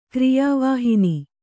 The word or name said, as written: ಕ್ರಿಯಾವಾಹಿನಿ